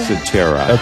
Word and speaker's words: Cetera: How do you say his last name? Is it Peter Cetera? – Cetera. – Okay. – Yeah. – I want to make sure I got that right.